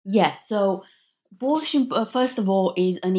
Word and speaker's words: borsch: Now, also another big part of your book deals with something called, and I hope I pronounce this correctly, borsch.